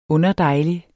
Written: underdejlig

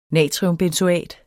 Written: natriumbenzoat

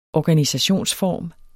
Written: organisationsform

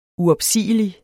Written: uopsigelig

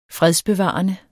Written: fredsbevarende

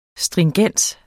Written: stringens

